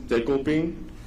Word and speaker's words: Dekopin: So his name is Dekopin. That's a Japanese name, and I figured it would be hard for American people to pronounce it. So he has an American name, and it's Decoy. So yeah, you could call him Decoy or Dekopin.